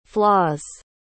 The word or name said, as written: flaws